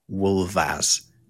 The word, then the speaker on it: wulfas: W-U-L-F-A-S, but an F in Old English, if it appears in the middle of a word and is between two vowel sounds, or L in this case, it makes a V sound. So wolf becomes wolvas.